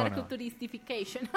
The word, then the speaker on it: Narcoturistification: Yo lo dije mal, ¿sí? Narcoturistificación no es, es no, narcoturistification.